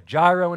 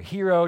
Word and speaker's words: gyro: He's got a gyro in his hand, or a hero, depending on how you pronounce it.